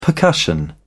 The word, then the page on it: percussion: British English